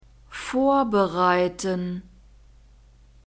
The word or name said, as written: vorbereiten